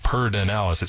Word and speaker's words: Pedernales: It says, we pronounce it Pertinalis. It's spelled P-E-D-E-R-N-A-L-E-S.